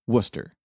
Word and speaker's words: Worcester: The British people pronounced Worcestershire, Worcester. – Worcester. – The name of the town.